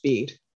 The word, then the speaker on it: Bede: I can't believe I forgot to mention one of my other favourite characters, whose name is actually apparently pronounced Bede.